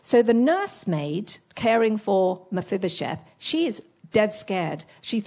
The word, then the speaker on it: Mephibosheth: He's got a very long name, Mephibosheth. Can you say that? Mephibosheth. 1, 2, 3, 4, 5 syllables. Mephibosheth. 4, sorry. Mephibosheth.